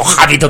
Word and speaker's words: Habit: Claro, bueno, hay que decir que yo pronuncio muy bien el inglés, pero es Habit, The Habit of the Rabbit. Ya ves que digo Habit of the Rabbit, pero es de Habit, de hábito, con una T solo.